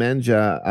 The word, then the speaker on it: Menja: Is it Fenha and Menha or Fenja and Menja? – Fenja and Menja.